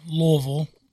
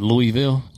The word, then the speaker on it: Louisville: Now, let me just say it right. Louisville. That's you can. – It's not Louisville? – Louisville can be accepted, but Louisville, absolutely not.